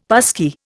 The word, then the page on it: passkey: Indian